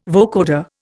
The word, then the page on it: vocoder: Indian